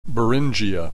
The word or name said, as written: Beringia